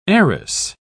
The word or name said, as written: arris